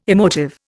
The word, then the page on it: emotive: Indian